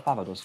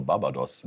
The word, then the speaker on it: Barbados: Man, das ist aber eine tolle Aussprache, Barbados. – Barbados. – Ich dachte, das heißt Barbados. Rihanna kommt ja nicht aus Barbados, Rihanna kommt aus Barbados. – Ja, aber da, wo ich herkomme, sagt man Barbados.